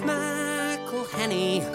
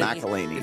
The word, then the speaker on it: McElhenney: Pronouncing all those N's and E's and H's can perplex him. So here's a little birthday gift from all your mates in Wrexham. It's McElhenney. What ways to massacre and mispronounce it, there are many. – McElhenney. – It's McElhenney. If there was doubt, now there won't be any.